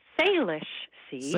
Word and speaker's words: Salish: Salish Sea. – Salish Sea. I think that's an indigenous word that's renamed what was the Straits of Georgia. – Right. – So it's the Salish Sea, but it is something that I love doing and have kept up doing even despite cooler temperatures recently.